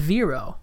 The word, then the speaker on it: Vero: About this little article that was explaining what we just mentioned, this new social media craze people have been raving about, and it is called Vero. – V-E-R-O. – V-E-R-O. And rhymes with zero.